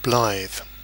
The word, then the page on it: blithe: British English